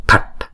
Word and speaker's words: phạch: Chữ phạch, chữ B phở này nè là đọc đứng một mình đó, chúng ta đọc là p. Còn chữ P H là đọc là b b. Rồi chữ T này có dấu nặng là chúng ta đọc là t. Đọc uốn cong lưỡi lại và búng ra t. Thì chữ này chúng ta đọc là phạch phạch phạch.